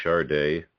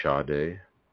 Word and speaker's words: Sade: And you pronounce it correctly. You say Chardet, but I always say Sade. But another funny thing on that first record that I got of hers, underneath her name in parentheses or in brackets or something, it said it phonetically spelled her name Chardet. So people, Americans, so they would know how to say that.